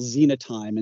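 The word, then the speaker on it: Xenotime: Yeah, I would say that the ones that we're most concerned about right now is a group that we call Zenotime. And Zenotime, depends how you want to pronounce the X in the front.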